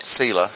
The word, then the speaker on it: Selah: And notice at the end of a statement about confidence, he says, S-E-L-A-H, Selah. We call it Selah in English, but that's not the Hebrew pronunciation. Selah.